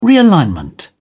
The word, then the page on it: realignment: British English